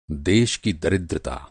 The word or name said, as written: दरिद्रता